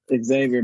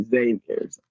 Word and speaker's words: Xavier: I don't hear too many Xaviers a lot. And then people pronounce it differently because they'll say Xavier, but it's Xavier. Okay. The X is not pronounced.